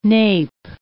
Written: nape

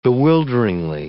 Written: bewilderingly

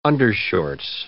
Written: undershorts

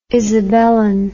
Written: isabelline

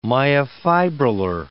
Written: myofibrillar

